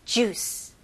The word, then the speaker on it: juice: 영어로는 이음절이 아니고 이름절입니다. 그래서 juice. juice. 그리고 마지막에 모음 소리가 나면 안 돼요. juice라고 하면 안 되고, 마지막 소리가 이 c 발음, s, c 이렇게 섞인 발음이죠. juice. juice. okay? juice가 아니고요.